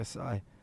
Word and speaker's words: Tsai: I would say Clara Wu Tsai. I don't think you pronounce the T. – Oh, you're probably right. So it might be, yeah, Tsai.